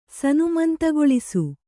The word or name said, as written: ಸನುಮಂತಗೊಳಿಸು